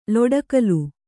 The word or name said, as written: ಲೊಡಕಲು